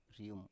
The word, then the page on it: rium: Biak